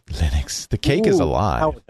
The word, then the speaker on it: Linux: For a long time when you installed Linux, it would come with one sound file that would say, my name is Linus Torvalds and I pronounce Linux.